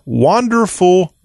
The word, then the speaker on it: Wanderful: And it comes to us from Our Wonderful Journey. And I said that right. Wonderful. W-A-N-D-E-R-F-U-L. As in, like, you go wander, but it's Wonderful Journey. And the Twitter account is Our Wonderful. O-U-R-W-A-N-D-E-R-F-U-L.